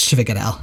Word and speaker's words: sfogliatelle: It's spelled like S-F-O-G-L-I-A-T-E-L-L-E and pronounced Schwigadel.